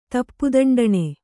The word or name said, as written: ತಪ್ಪುದಂಡಣೆ